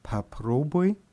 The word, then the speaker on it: попробуй: Also bekomme ich im Singular попробуй und im Plural попробуйте. – Хорошо. Попробуй, попробуйте. Hier ist die Betonung auf der zweiten Silbe.